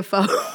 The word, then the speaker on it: pho: Yeah, fo.